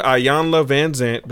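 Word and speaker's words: Iyanla Vanzant: Williams has some things to say about she was with Ayanla Van Zant.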